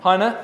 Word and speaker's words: Heine: Heine, have I pronounced that right? Heine, thank you very much.